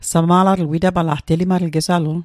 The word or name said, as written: sama'latl